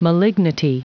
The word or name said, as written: malignity